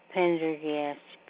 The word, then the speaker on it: Pendergast: A-N-N-E-N Pendergast. P-E-N-D-E-R-G-A-S-T.